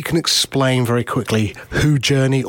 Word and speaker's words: JRNI: Glenn, you are the chief architect now of a company called Journey. – Yep. – Which is spelled J-R-N-I. – Yeah, which took us a while to get the right spelling and the right pronunciation, but it's good.